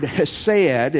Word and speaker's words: hesed: It's a great word, hesed. It's spelled with a C-H-E-S-E-D. That's how we would transliterate that from the Hebrew to the English.